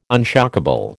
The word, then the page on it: unshockable: American English